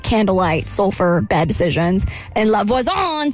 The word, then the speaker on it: La Voisin: So she meets this person named La Voisin. It's the correct pronunciation.